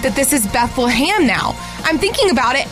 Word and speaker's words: Bethlehem: But I'm telling you, it's Beth-LUH-HAM.